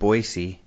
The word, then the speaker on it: Boise: And I don't know if you noticed in spacing out last week, I said Boise. – I didn't notice that, but good job, dude. I'm proud of you. Thank you. – Because you taught me that's how the locals say it.